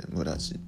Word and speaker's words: 連: 村地は連続の連の字で村地って読む